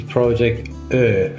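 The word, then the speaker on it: Ö: It's Project O, which is this funny Swedish-Finnish letter with O, with the dots, so it's O.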